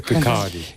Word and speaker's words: Kirkcaldy: pronunciado en Kirkcaldy, en Escocia, en 2005, el discurso de Greenspan. No, se pronuncia Kirkcaldy.